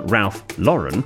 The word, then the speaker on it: Ralph Lauren: William, you've corrected him a couple of times, but I feel it needs more attention on Ralph Lauren is pronounced like the girl's name, Jordan.